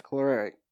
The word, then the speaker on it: choleric: Personality types, choleric. Say it again. – Choleric. – Choleric. Because it's not pronounced like it's spelled.